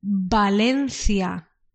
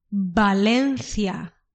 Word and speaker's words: Valencia: The B and the V have the same pronunciation in Spanish. So then, when you say Barcelona, it's with a B, Barcelona. But when you say, for example, in English you call Valencia, in Spanish we say Valencia. Valencia. We haven't got the V sound.